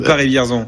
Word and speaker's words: Paris-Vierzon: Paris-Vierzon ou Paris-Vierzon? – Ah non, lui, on prononce bien en Paris-Vierzon. – À la française, donc.